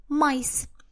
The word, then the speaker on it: mice: Но никто никогда не знает, что множественное число от «маус» будет «майс». Майс, пишется это слово M-I-C-E, то есть слово полностью меняет э, свою орфографию, оставляется только буковка M.